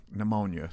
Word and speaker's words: pneumonia: Like pneumonia, silent pee.